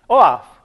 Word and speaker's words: OAuth: OAuth. You guys do pronounce it correctly. OAuth, correct.